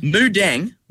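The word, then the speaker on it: Moo Deng: Moo like a cow. D-E-N-G. Mudeng. – Mudeng. Okay. Yep. Okay. Now, Mudeng. – You tell me you know Mudeng. – I have a Mudeng shirt.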